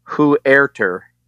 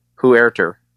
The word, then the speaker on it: Huerter: So I'm looking at a 2018 NBA draft pronunciation guide, and it is Huerter. Who, and then emphasis on air as in A-I-R, and then T-E-R. Huerter. Kevin Huerter.